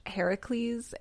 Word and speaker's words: Heracles: Something I always forget about Hercules, because that's how we pronounce it, is it's actually pronounced Heracles, and it roughly translates for the glory of Hera, which was a huge reason Hera hated him so much, because...